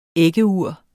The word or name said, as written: æggeur